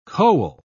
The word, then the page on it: koel: British English